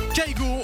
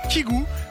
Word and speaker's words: Kygo: Kaigo, ou devrais-je plutôt dire Kigou, puisque c'est comme ça que l'on devrait prononcer son nom d'artiste. Alors vous inquiétez pas, il accepte également que l'on prononce Kaigo.